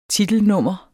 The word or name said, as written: titelnummer